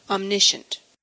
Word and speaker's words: omniscient: We had a listener send me a helpful video in response to my egregious mispronunciation of the word omniscient. The random YouTube video he sent me set me straight. – Omniscient.